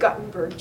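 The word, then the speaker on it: Guttenberg: I pronounce it Gutenberg, G-U-T-T. – Sorry. – It's okay, E-N-B-E-R-G.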